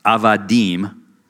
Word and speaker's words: avadim: It's pronounced eved or plural uh, avadim, and this is the word that means slave.